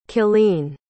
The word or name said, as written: killeen